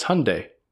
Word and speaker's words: Hyundai: Well, it's like when Hyundai did their Super Bowl ads. On how to pronounce Hyundai. – Oh, yeah. – Remember, it's Hyundai. Like Sunday.